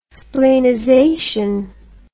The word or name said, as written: splenization